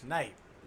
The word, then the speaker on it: Snipe: Charles and Holt is spelled H-O-L-T. – Yeah. And then it's OG Snipe, no S. – Okay. OG Snipe, no S. – Snipe.